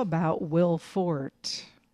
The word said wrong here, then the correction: Forte: Maybe it's pronounced Forte. I'm going to be honest. – I think it's Will Forte. Yeah, there is an E on the end.